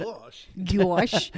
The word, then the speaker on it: Gosch: It's about Johnny Gosch, I think is how you say his name, and he was the first kid that they ever put Gosch.